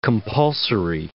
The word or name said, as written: compulsory